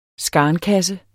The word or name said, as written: skarnkasse